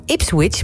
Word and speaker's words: Ipswich: Hij maakt de overstap naar het Engelse Ipswich. Moet ik het wel goed uitspreken? – Ja. – Spreek het goed uit? – Ja, zeker. – Ipswich Town.